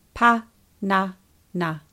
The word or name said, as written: panana